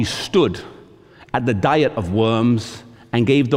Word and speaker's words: Worms: He would later go on to this place, Worms, or as we say in English, because we don't pronounce the V, we say W, we say Worms.